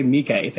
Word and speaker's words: Miike: Takeshi Miike, thank you.